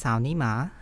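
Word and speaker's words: Sao Ni Ma (草泥马): And so the grass mud horse in Chinese is pronounced Sao Ni Ma.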